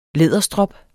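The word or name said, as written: læderstrop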